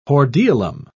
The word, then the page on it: hordeolum: British English